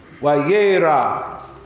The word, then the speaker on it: Vayera: And he appeared, Wayira, in his proper pronunciation.